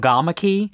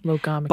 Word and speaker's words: logomachy: So like nowadays, like the most common accepted emphasis is on the G. So it's right. Logomachy. – Logomachy.